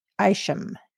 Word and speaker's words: Isham: Her aunt is the former Donna Linson, Art's sister, who is married to composer Mark Isham.